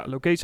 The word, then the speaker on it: Lokesa: Eh, we hebben Lokesa, Lokesa zeg ik het goed? Ja, Lokesa hebben we nog niet eens benoemd.